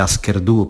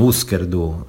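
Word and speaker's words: Hüsker Dü: Che poi è la stessa cosa che faccio con tanti altri nomi, ad esempio con un gruppo che, quando ero ancora piccolo, ho deciso che si chiamasse Asker Du, per esempio, e che adesso invece scopro tutti pronunciano Usker Du,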